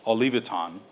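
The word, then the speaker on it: Olivétan: His own cousin, Pierre Robert, this is a French pronunciation of his last name, Olivetan.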